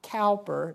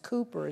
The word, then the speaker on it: Cowper: Some of you know it's not Cowper, it's pronounced Cooper as if it were spelled C-O-O-P-E-R.